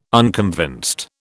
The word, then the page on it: unconvinced: British English